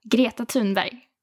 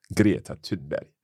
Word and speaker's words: Greta Thunberg: Greta Thunberg. – Greta Thunberg.